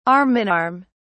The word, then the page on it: arm in arm: British English